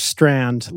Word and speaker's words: Strand: Oh, my name is Strand, like the bookstore in New York.